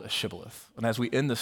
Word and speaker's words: shibboleth: They couldn't pronounce it right. It wasn't part of their dialect. And so whenever they said sibboleth instead, they knew that they were an Ephraimite, and they put them to death.